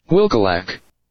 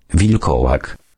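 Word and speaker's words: wilkołak: Okay, let's hear it in Polish. Polish pronunciation. – Wilkolak. – Oh, that sounds right. Sounds here's another Polish pronunciation. – Wilkolak. – Wilkolak. All right, that sounds about right.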